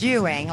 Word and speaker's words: Ewing: Well, I am originally from Dallas, Texas, and, you know, my last name's Ewing, like J.R. on it. So I'm glad when that movie came on because everybody could pronounce my name now.